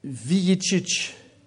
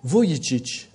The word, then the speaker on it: Vujicic: Il y a Nick Vujicic. C'est comme ça que ça se prononce. Vujicic.